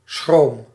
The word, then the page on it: inquiétude: France; [ɛ̃.kje.tyd]